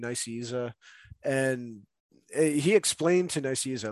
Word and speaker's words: Nicieza: So I'm pretty sure it's pronounced Fabian Nicieza. – Nicieza. Okay. I had no idea how to say it. All right. Sorry, continue. – We could be wrong, but I'm pretty sure it's Nicieza.